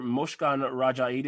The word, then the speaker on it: Mozhgon Rajee: And then next up, we have Dr. Mozhgon Rajee.